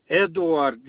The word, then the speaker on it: Eduard: Edward, E-D-U-A-R-D. – You said E-D-U-A-R-D? – Hmm.